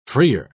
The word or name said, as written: freer